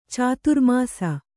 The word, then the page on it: ಚಾತುರ್ಮಾಸ: cāturmāsa